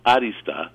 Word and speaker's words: arista: I put the accent on the A, like arista, because it's actually a Greek word,